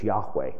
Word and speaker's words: Yahweh: Just know that the real name is Yahweh. And by the way, this is, most scholars today really believe, high probability that that's how that name is pronounced, going back to ancient documents.